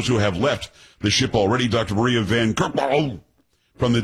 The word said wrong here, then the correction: Van Kerkhove: It's Van Kerkhove. Kerkhov. I'm pretty sure that's how you pronounce it.